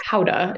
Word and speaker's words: Gouda: I mean, I'm like, I always say like I'm American, I say Gouda, but Gouda is like, I mean, a Dutch person would say that's a terrible accent, but yeah, it's Gouda is the more like the correct pronunciation. But yes, I grew up saying Gouda and I still say Gouda.